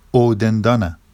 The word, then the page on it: owdendɑna: Vafsi